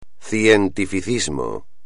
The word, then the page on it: cientificismo: espagnol